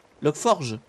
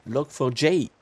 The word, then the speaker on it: Log4j: Ça se prononce Log4j. Logforge? Bah non. À l'anglaise, Log4j. Log4j.